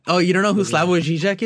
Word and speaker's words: Slavoj Žižek: It's Slavoj Žižek.